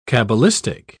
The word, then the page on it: cabalistic: British English